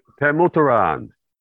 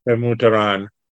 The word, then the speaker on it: Pemuteran: Pemuteran. – Pemuteran.